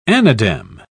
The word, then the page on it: anadem: British English